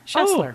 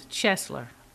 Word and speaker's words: Schessler: Rhymes with wrestler. Schessler. – Oh, Schessler. Oh, okay. You know, the reason I got I stumble on it is I have a friend in Chicago who pronounces it I can't even say it. – In German, it's Schessler. – Ah. – And my last name is Zopf, and we just say Zopf Schessler. Much easier.